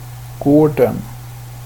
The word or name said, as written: gården